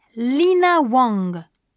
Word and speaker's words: Lina Wang: Bonjour, je m'appelle Lina Wang. – Comment ça s'écrit ? – L-I-N-A-W-A-N-G.